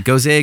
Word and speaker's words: Gose: Yeah, every time Corey and I like debate, is it goze, goza? I think it's supposed to be goza, but... – It's spelled G-O-S-E.